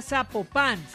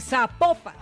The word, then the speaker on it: Zapopan: Oiga, nos escriben los oyentes y que no se pronuncia Zapopan, sino que se pronuncia Zapopan.